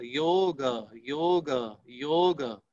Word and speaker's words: योग: इसी प्रकार यदि आपको योग का शुद्ध उच्चारण अपने आप सीखना है तो आप उल्टा जब उल्टा पढ़िए, गयो गयो गयो गयो, ये आप बार बार पढ़ेंगे तो आपको गयो गयो गयो गयो योग योग योग, ये स्वतः आपको उच्चारण आ जाएगा।